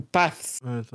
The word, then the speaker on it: Paths: On Alors, alors, euh, je sais pas comment on prononce avec un S TH euh, à la fin. Un ah, THS. Path. Paths ou paths Paths. – Paths. Ouais. – Paths unknown.